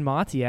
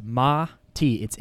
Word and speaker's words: Mati: And this brand Mati actually picked us up as a sponsor for the Thrive Daily podcast. So Mati is literally pronounced Ma-T. It's M-A-T-I, but it's pronounced Mati.